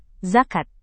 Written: zakat

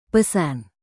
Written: pesan

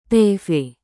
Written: tv

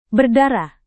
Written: berdarah

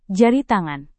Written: jari tangan

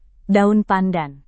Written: daun pandan

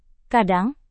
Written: kadang

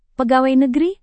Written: pegawai negeri

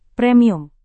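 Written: presentasi

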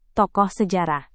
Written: tokoh sejarah

